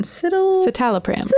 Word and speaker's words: citalopram: Citalopram. – Citalopram.